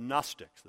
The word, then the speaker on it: Gnostics: They were called the Gnostics, the beginning of the Gnostics, spelled with a silent G. Silent G, N-O-S-T, and so on.